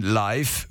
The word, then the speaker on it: Live: Barney war überzeugt, dass das I in Live kurz wie in Schiff ausgesprochen wird. Ich hielt ihm entgegen, dass es in dem Fall mit F, also Liv, geschrieben werden müsste und das ergebe keinen Sinn. Da es sich um eine Live-Platte handelte, konnte es nur so ausgesprochen werden wie in Steif oder Reif.